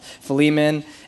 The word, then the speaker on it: Philemon: So naturally, um, my tendency was to pronounce it Philemon, and I was just like, it's, I, I know that. That's, that's familiar to me. Um, but uh, you know, I, I was uh, actually studying for it and talking to Carl and was like, oh yeah, I found out like it's pronounced Philemon, and he's been pronouncing it like Philemon for the entire time. So he's like, oh, I have to correct myself too, and.